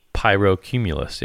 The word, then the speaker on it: pyrocumulus: Let me give it a whirl. Pyrocumulus. Yeah, you were right.